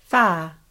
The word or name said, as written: wha